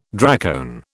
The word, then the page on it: dracone: British English